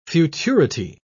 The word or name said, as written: futurity